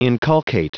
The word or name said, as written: inculcate